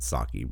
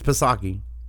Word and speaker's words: Psaki: And yes, I know she pronounces it Psaki before anybody decides to be a smartass. But it has a P, and I do not discriminate against P's. So it's Psaki.